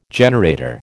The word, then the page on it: generator: American English